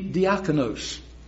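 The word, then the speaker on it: diakonos (διάκονος): Because if you heard it in the Greek, and I think I will pronounce it correctly, it would be diakonos.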